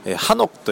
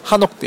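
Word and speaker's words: ハ ノク (한옥): で、このソチョンエリアはですね、昔からの韓国の伝統的な建物である、ハノクという、韓国の館に、屋台の屋ですね、家屋の奥と書いてハノクというんですけれども、それがたくさん残っているエリアとして有名なんですね。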